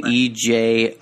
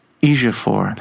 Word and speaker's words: Ejiofor: Chiwetel, first name. Last name. – Oh, Chiwetel. Yeah. – Last name Ejiofor. – Ejiofor. That's it. Ejiofor.